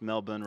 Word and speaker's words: Melbourne: Pronounced Melbourne, right? – You did pretty good. – Yeah. – Yeah. – I've been corrected before, but I stick with my American. – No, you've Melbourne. – Done well. There's a lot of people butcher it, so.